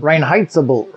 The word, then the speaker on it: Reinheitsgebot: Is that how you pronounce it in German? – Reinheitsgebot. – Yeah. – Something like that.